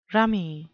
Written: rummy

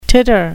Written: titter

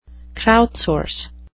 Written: crowdsource

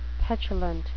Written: petulant

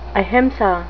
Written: ahimsa

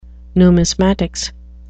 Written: numismatics

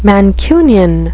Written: mancunian

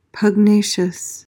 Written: pugnacious

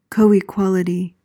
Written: coequality

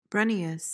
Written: brunneous

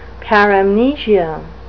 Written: paramnesia

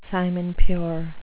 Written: simon-pure

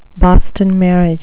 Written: Boston marriage

